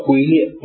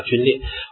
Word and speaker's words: 皲: 第一个叫做勿使灵皲裂啊，勿使灵皲裂，不叫龟裂，不是龟啊，就很像龟甲那个龟，它这个发音呢叫皲啊，皲裂，